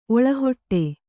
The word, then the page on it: ಒಳಹೊಟ್ಟೆ: oḷahoṭṭe